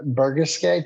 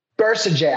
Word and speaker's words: Bercegeay: Today, we are sitting here with Hannah Bercegeay. Did I pronounce that right, Hannah? – No, it's Bercegeay. – Bercegeay.